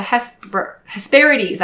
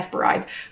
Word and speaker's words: Hesperides: Retrieve 3 garden apples from the garden of the Hesperides. That's how you say it. I was going to say Hesperides.